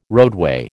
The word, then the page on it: roadway: American English